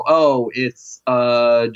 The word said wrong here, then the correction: Holmseth: Did I say that right? It's Holmseth.